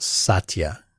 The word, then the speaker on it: Satya: And before Dreadnought writes in, I believe he works for Microsoft, it's pronounced Satya. – Satya.